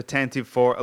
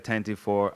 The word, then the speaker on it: Tantive IV: How do you pronounce that? I say the Tantive IV. A lot of people say the Tantive IV. I am aware that the audio drama from back in the 80s, I think, in that it was pronounced Tantive IV. All right. That seems weird to me. But pronounce it however you like.